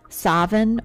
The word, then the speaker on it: Samhain: It's pronounced Samhain, Savin, or Samhain. Well, I think that might be the American version, but whatever.